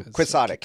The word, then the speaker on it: quixotic: Ooh, quixotic. Yeah, that's how you say it. Quixotic. – Named after Don Quixote.